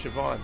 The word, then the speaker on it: Siobhan: Siobhan is spelled S-H-I-O-B-A-N, and it's Siobhan.